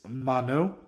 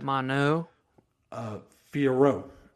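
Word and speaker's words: Manon Fiorot: pronouncing names. It's Mano. They don't pronounce the last end. – Mano Fiorot.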